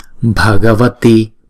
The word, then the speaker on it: Bhagavati: Chữ B đứng một mình thì chúng ta đọc là B. Chữ B ghép chung với chữ H chúng ta đọc là B. Bhagavati, Bhagavati, Ashta, Ashta.